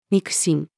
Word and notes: 肉親: female

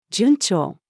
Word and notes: 順調: female

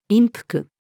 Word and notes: 陰腹: female

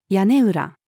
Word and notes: 屋根裏: female

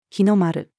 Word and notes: 日の丸: female

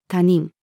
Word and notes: 他人: female

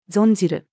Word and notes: 存じる: female

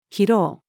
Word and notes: 拾う: female

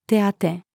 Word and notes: 手当: female

